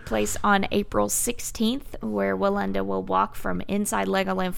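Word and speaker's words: Wallenda: Please pronounce this name. I'm scared I'll mess it up. – it's Nick Willenda. – Nick Willenda. Okay. I was worried it was going to sound differently than it's spelled.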